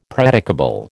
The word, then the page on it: predicable: American English